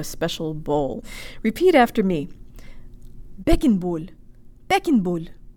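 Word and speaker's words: Beckenbauer: Make sure to protrude your lips so you get a nice full O sound. Beckenbowl.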